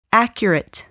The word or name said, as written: accurate